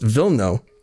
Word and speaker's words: Wilno: It's well known that Wilno, or properly pronounced Wilno, was the first Polish settlement in Canada, as you mentioned before.